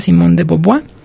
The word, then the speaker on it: Simone de Beauvoir: Si bien mi francés no es muy bueno, pero se escribe Simone de... continuando con la B, la E, A, U, V-O-I-R, pero sin, se pronuncia Simone de Beauvoir, y propiamente su libro se llama La vejez.